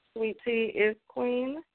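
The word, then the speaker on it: Swee Tee Iz Queen: Sweet Tea is Queen, which is spelled SWEE, capital TEE, IZ, Queen.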